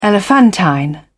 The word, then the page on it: elephantine: British English